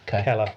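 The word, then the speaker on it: Celer: They were commanded by a man named Keller, or Seller, depending whether that's a hard or soft C. I'll let you choose. – K. It's a hard C sound. – Keller.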